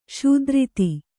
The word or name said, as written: ಸ್ಥಿರತೆ